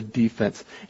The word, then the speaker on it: defense: And notice the pronunciation there, too, friends. If you're in a courtroom, you might say you are trying to defend somebody, right? Or maybe you're talking about the defense. But here in this case, we're pronouncing it defense. If you're defending the goal and defending your team or defending the other team from getting a point, then you are saying you are on the defense.